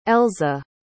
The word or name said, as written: elza